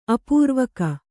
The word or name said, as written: ಅಪೂರ್ವಿಗ